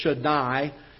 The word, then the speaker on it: Shaddai: Job is one of the few writers in history, or in the Old Testament, that uses the Hebrew name, and the English pronunciation of it is Shaddai.